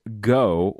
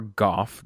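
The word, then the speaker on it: Gough: Written by one Stephen Moffat and directed by Lawrence Goh, or Goff, depending on where you're from.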